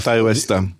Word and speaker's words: Ty West: Ty West. – Teď mě oprav výslovnost Ty West, takže režiséra Ty Westa, film, nebo první část americké filmové ságy Horizont a film Motorkáři.